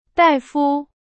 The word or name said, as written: 大夫